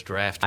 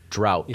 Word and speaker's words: draught: No, it's draft beer. – I think I said drought.